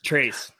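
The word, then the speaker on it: Trayce: For everyone that isn't familiar with this, Metcalf and I had some beef last year about Tracy Jackson Davis. And it wasn't even like beef. It was it might help if you pronounce his name correctly. – Trace. Sorry. Jeez.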